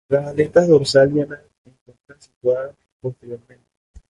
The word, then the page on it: posteriormente: spanisch